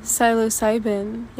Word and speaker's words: psilocybin: the use of things like psilocybin, is that how you say it? How do you say it? – Psilocybin, yes.